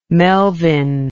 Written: Melvin

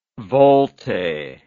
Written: volte

